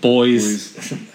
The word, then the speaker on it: buoys: Boys. However you pronounce it. – It's definitely boys.